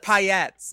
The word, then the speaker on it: paillettes: Paillettes? – Paillettes. It's French, and it's like, it's one of those things that doesn't make sense in the English language, and you just learn to say it right.